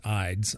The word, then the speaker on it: Ides: It's in Latin, you would see I-D-U-S, but then we pronounce it Ides, I-D-E-S.